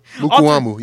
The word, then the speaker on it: Mukuamu: Mukuamu, I think. But he had all three of the picks. – Mukuamu, yep. – Mukuamu, Israel Mukuamu, with all three picks.